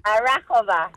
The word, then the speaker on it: Arahova: But before we get to the game, I have somebody on the phone who's saying I am pronouncing Arahova wrong. – Oh, really? – Yeah. So how do you pronounce it? – Arahova. – Arahova. – No. – My Greek is horrible. – Arahova. – Come on, Nikki, get with it. – Arahova. – Arahova. – Arahova. – Yeah.